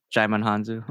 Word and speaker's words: Djimon Hounsou: What's uh Digimon Handu? How do you say that? Djimon Djimon Hounsou.